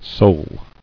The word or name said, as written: Soling